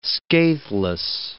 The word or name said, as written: scatheless